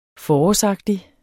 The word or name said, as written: forårsagtig